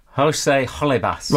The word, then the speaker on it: Holebas: How would you say it again? Jose Holebas.